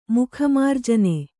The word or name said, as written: ಮುಖಮಾರ್ಜನೆ